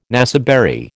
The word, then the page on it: naseberry: American English